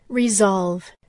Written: resolve

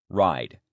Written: ride

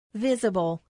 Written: visible

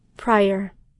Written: prior

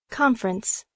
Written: conference

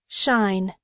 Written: shine

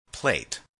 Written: plate

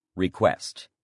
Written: request

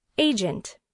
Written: agent

